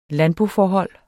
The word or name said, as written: landboforhold